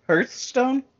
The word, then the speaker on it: Hearthstone: Can you spell it? Hearth, H H E A R T H, and then Stone S T O N E. So Hearth Hearthstone. But it's pronounced Hearth.